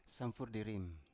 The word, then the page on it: samfur di rim: Biak